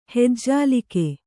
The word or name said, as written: ಹೆಜ್ಜಾಲಿಕೆ